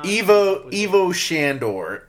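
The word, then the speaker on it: Ivo Shandor: The next Ghostbusters movie, it's gonna be all focused on Gozer again, because they are at the Sandor. Am I saying that right? God. – No, I know what you're trying to say. It's an unusual name. I cannot. – Evo Shandor.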